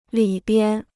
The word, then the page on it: 里边: lǐ bian